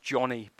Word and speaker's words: Joni: You know, Joni, it's actually pronounced Johnny, by the way.